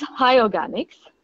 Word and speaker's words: HIORGGANIKS: So it's high organics.